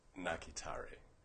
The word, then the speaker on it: Nakitare: It's Nakitare. – Nakitare. How do you spell that? – That's N-A-K-I-T-A-R-E.